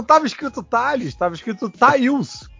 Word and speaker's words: Tails: Não, Tails eu sempre pronunciei Tails. – Pô, mas quem era pra você? Era Thaís? Thales!